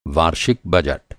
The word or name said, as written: बजट